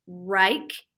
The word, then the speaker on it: Wrike: And then also, I think it's pronounced Rike. It's WRIKE.